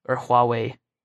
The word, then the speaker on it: Huawei: Or Huawei, I think is the way it's pronounced.